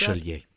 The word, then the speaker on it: Bachelier: Bachelier. – That? Bachelier.